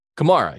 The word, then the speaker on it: Kamara: Oh, but by the way, Kamara, you're saying it right now. I've heard it that way as long as he's been in the NFL that I can recall.